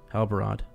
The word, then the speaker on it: Halbarad: And, They ask who he is, and so how do you say his name? Is it Halbarad? – Halbarad? – Yeah. – Halbarad.